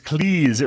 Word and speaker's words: Cleese: It's Cleese. It rhymes with cheese."